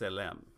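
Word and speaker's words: tselem: So, image, the word image in the Hebrew is pronounced selem, and that's T S E L E M, and it's a masculine.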